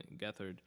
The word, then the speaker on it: Gethard: it's spelled get hard uh, his last name um, but it's uh, pronounced gethard um,